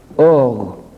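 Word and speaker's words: or (אור): Le mot hébreu qui désigne la lumière, c'est or, qui s'écrit Aleph, Vav, Resh. Or, l'Aleph est silencieux, le Vav est prononcé O, et le Resh, c'est un R.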